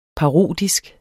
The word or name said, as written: parodisk